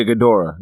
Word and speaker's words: Ghidorah: I'm probably butchering it. Ghidorah? For Ghidorah? – Yeah, Ghidorah, yeah.